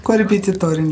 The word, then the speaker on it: Korrepetitorin: Heute gehen wir der Frage nach, was denn eigentlich eine Korrepetitorin so macht. Habe ich das richtig ausgesprochen? – Korrepetitorin, ja.